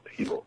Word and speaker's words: Hilo: you are still located in Hilo, Hawaii? – That's correct, Jeremiah. Thank you for having me on your show. And Hilo is pronounced correctly. You have it right, Hilo.